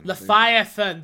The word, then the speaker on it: Leviathan: Leviathan. That's how you say it. You're right.